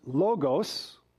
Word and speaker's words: logos: L-O-G-O-S. It is either pronounced logos, which is the easiest way to remember, logos, or logos. doesn't matter which camp you want to fall into.